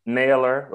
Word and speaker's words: Naylor: Naylor, like a nail, like with an E-R at the end.